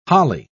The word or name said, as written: holly